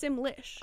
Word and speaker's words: Simlish: um, it sounds like Simish. – Oh, it for sure sounds like Simlish. Yeah. – Oh, did I say that wrong? – Sim, I think it's Simlish. I I've never played this.